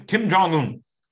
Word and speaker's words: Kim Jong Un: I may be saying his name wrong. Kim Kim Jong Un. Yeah, if you say it faster, better.